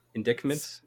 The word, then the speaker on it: indictments: Indictments? Is that how you pronounce it? – Indictments, yeah. – Indictments, yeah. – Oh, well, fuck. That's stupid. – Sorry, I was like, what does that mean? – There's a... So you don't pronounce the... – Nope. – The C in there?